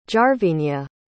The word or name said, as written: jarvinia